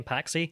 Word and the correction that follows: Psaki: Jen Psaki.